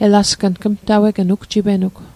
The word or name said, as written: elasgng